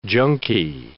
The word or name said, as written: junkie